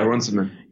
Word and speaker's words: Runciman: Yeah, Runciman.